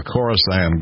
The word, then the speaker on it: Khorasan: K-H-O-R-A-S-A-N, Khorasan.